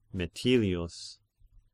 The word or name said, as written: Metilius